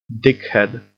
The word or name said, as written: dickhead